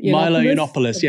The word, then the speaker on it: Milo Yiannopoulos: Remember that, I don't know how to pronounce his name, Milo Yiannopoulos. What do you know how to pronounce his last name? – Milo Yiannopoulos.